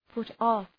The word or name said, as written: put off